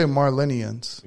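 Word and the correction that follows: Marleyans: Yeah, Marlenians. – Yeah, but it's not Marlin. – It's Marlins, right? – Marlians. – Marlians.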